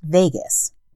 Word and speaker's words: vagus: It's pronounced vagus, like the city, but what happens in the vagus nerve doesn't stay in the vagus nerve.